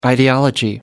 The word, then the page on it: ideology: American English